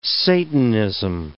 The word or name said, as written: satanism